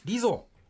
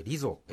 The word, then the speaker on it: Lizzo: 次は、リゾ。 LIZZO と書いてリゾ、